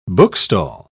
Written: bookstall

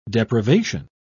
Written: deprivation

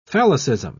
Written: phallicism

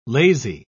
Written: lazy

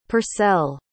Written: purcell

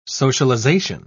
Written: socialization

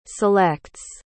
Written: selects

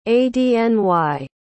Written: adny

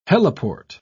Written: heliport

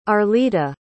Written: arleta

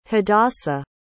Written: hadassa

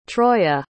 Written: troya

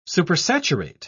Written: supersaturate

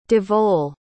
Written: Devaul

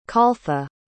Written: caltha

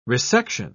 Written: resection

